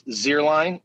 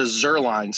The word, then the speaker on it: Zierlein: It is the second vowel of I-E-E-I is the long vowel, so the long vowel sound. But unfortunately, somewhere along the Kansas farmlands where both of my parents come from, it turned into Zierlein. So it's pronounced like the kicker.